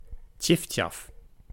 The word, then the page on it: tjiftjaf: /tjɪftjɑf/; /tʲɪftʲɑf/